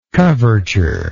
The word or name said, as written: coverture